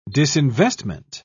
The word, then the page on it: disinvestment: British English